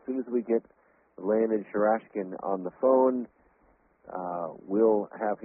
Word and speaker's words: Sharashkin: I hope I'm pronouncing that right, Michael, am I? – Sharashkin, yes, that's okay. The pronunciation.